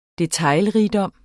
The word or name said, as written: detaljerigdom